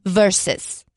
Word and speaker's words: versus: Why did everybody start saying verse? It's not how we talk here. – I was just doing the abbreviation, the VS period. – Right. Even still, it is pronounced verses.